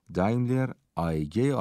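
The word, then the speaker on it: Daimler AG: یا به تلفظ آلمانی دایملر آیگه آلمانی 50 میلیون دلار برای شرکتش جذب کرد.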